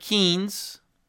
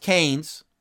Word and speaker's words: Keynes: John Maynard Keynes, sometimes pronounced Keynes, a British economist, 1883 to 1946, he became the poster child for government deficit spending to stabilize and to prosper an economy.